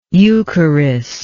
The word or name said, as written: eucharistically